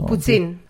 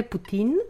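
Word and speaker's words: poutine: Οι Κεμπεκού έχουν μια ιδιαιτερότητα στην προφορά. Το, το, το, το ταφ, ειδικά μα είναι δύο ταφ μαζί, το κάνουν τσ. Οπότε λέγεται, κανονικά είναι πουτίν, αλλά εμεί στο Κεμπέκ το λέγαμε πουτσίν.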